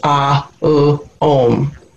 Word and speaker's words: om: It's traditionally pronounced in three different syllables, ah, u, om.